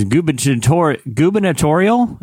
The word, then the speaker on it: gubernatorial: Is that, am I saying that right? – Yeah. You got it. Just double down, man. Just own it. Just power through. Into it. A gubernatorial. I feel like I'm adding an extra syllable. I feel like there's a lot of syllables in that word.